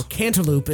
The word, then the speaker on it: cantaloupe: You know, so like instead of eating like a honeydew or a kaloop. – Yes. – Or cantaloupe, as it's correctly pronounced.